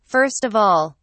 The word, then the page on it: first of all: British English